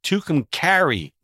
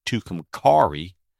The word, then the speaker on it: Tucumcari: And it's Tucumcari, New Mexico, not Tucumcari.